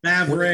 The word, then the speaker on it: Favre: And the guy couldn't even pronounce his name, Brett Favre or something like that. – Favre, yep.